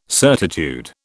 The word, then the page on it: certitude: British English